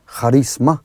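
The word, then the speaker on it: carisma (χάρισμα): Eso es cuando una persona tiene carisma, carisma como se pronuncia en griego, es cuando tiene cierta gracia de Dios. Carisma, charisma, ok.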